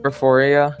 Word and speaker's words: Porphyria: Porphyria.